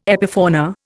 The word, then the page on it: epifauna: Indian